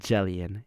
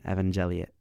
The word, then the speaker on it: Evangelion: First of all, correction on my pronunciation. Neon, nope. Yeah, Neon Genesis Evangelion. Not Jellion, Gellion, Evangelion. Oh my God.